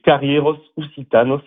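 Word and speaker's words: Carrièras Occitanas: C'est Carrieros Occitanos, ça se prononce.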